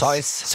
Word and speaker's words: Seuss: Es ist ein Animationsfilm aus Amerika vom Dr. Spricht man ihn aus Süß oder Seuss? – Seuss.